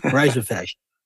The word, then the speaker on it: rhizophagy: Now, you may hear it pronounced differently. And the reason you hear it pronounced differently, I believe, is because you're hearing an Australian pronunciation. We pronounce it rhizophagy.